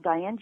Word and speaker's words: Dianne: Yes, Diane Jewell. My first name is spelled D-I-A-N-N-E. My last name is spelled J-U-H-L, Diane Jewell.